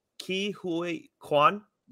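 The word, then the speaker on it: Ke Huy Quan: Ki Hui Kwan.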